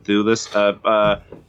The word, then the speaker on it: Thewlis: David, I don't know if I can pronounce Thewlis. – Yes. – David Thewlis.